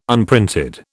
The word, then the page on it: unprinted: British English